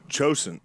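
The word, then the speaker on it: Chosin: Chosan or Kosan. – Oh, Chosan. Yeah, Chosan. Yeah.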